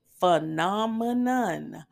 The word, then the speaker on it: phenomenon: And then, even right to this day, if you ask me how to spell phenomenon, and I'm going to pronounce it phenomenon because that's the proper way to say it.